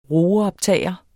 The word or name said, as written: roeoptager